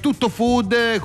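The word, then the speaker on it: TuttoFood: The Canter, speciale Tutto Food, qui a Milano, International Food Exhibition. Giusta la pronuncia, Fede? È stato bravo, no? Correttamente, ciao.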